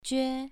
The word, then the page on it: jue: 2